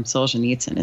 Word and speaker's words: Solzhenitsyn: It's time for a quote from Solzhenitsyn.